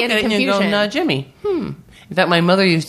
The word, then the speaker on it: Najimi: Because her name is Najimi. It's, you say it like it looks. It's not like some weird... – It's a weird name, but it's pronounced phonetically.